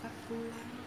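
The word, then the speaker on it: Bakkula: Bắc Cú Lá ngày này sống thọ Bắc Cú Lá hai chữ ca mình mới đọc là Bắc một chữ ca thì mình đọc là Bá thôi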